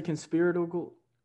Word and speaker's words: conspiratorial: Conspirical? Conspirical? – What? – How do you say conspiratorial? Conspiracy? – Conspiratorial? – Oh, conspiratorial thinking to a lot of people.